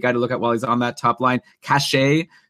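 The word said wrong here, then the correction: Kase: As far as I know, it's pronounced Kasha.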